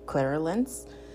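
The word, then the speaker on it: clairalience: I'm gonna spell it out for you because I'm not the best pronouncer. It's C-L-A-I-R-A-L-I-E-N-C-E, clairance.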